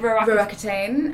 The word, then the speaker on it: Roaccutane: Well, it's, I think, because Roaccutane, it just, all it does is that it's pretty damn strong. – I had a friend who took how do you say it? – Roaccutane.